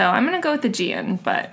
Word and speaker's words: Aegean: always, Aegean, too.